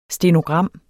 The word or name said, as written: STENOGRAM